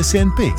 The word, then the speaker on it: CNP: Si legge Senpi, si scrive CNP.